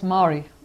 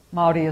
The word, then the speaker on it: Māori: It's Māori. But Māori is the correct pronunciation.